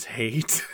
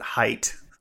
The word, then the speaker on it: Hayt: Mentally, I pronounced it height. – Oh, really? Uh-huh. I was saying hate, because there's a moment where a character says hate and then spells it, H-A-Y-T.